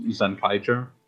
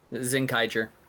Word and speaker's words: Zenkaiger: I misinterpreted it. If I saw it written in English, it's apparently pronounced Zenkaiger. – Zenkaiger.